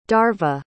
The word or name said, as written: darva